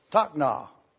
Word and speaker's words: Tanakh: The Tanakh in the Hebrew is pronounced, as I have it on the screen for you, Tanakh.